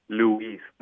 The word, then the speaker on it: Luis: Yeah, like, you see, like, in that case, you being a speaker, an English speaker, it'll be Luis. There's an emphasis on the U, and Spanish is just Luis.